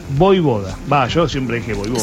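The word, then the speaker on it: Vojvoda: Es que la J se pronuncia como I. Es como Djokovic. – Voivoda, es fácil. – Es como Djokovic. Djokovic es con J en el medio. Bueno, Voivoda es lo mismo.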